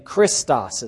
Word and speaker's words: Christos: It looks like this with the big key at the beginning, Christos is how you say that word. So if you turn the key into a CH and that little P-looking letter has the R sound in Greek, CHR, the I is like the I in English.